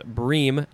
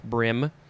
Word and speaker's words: bream: Next is the second form of the same word, but this one says it's pronounced bream, not brim, spelled the same.